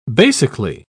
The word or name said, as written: basically